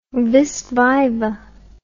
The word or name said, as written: vis viva